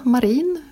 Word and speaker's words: Marin: Hon heter ju, ja, det stavas M-A-R-I-N kan vi säga. – Mm. – Och det är ju, dels är det ju ett svenskt ord, så det ligger ju nära till hans att tro att det ska vara marin. Och det är inget konstigt. Och många finlandssvenska talare säger så. Men hon är ju finländare, alltså finsk, och säger själv marin, mera, med betoningen på den första.